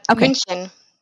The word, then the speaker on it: München: München. – Sagen oh. – We say, yes, München.